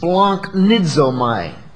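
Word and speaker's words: splanchnizomai: It's pronounced splanknizomai.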